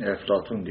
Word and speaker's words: Eflatun: Bizim hani Arapçada P harfi olmadığı için Eflatun diye telaffuz edilen filozof.